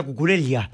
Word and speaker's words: Cucurella: Y encima nosotros nos reíamos porque yo le decía Cucurella y Espina le decía Cucurella, que creo que es la pronunciación lógica. – Correcto, sí, por ella. Español. No es italiano, sino español.